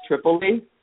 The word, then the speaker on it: Tripoli: My name is actually pronounced Tripoli, but I like how you say Tripoli.